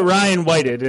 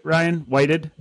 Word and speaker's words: Ryan Whited: And then we got Ryan Whited, and I'm pronouncing that right. Is that right, Ryan Whited? – Nailed it. Good job. Like the past tense.